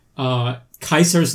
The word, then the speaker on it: Caesar: You have Kaisar's Legion, which I love how they fuck up the name like Kaisar because they, you know, they just assume that... like that's the pronunciation of Caesar. – They read it phonetically.